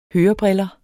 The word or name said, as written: hørebriller